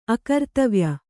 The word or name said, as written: ಅಕರ್ತವ್ಯ